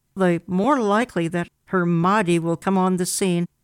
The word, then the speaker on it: Mahdi: So the Mahdi, an English speaker would pronounce it, it's really Mahdi, which basically means guided.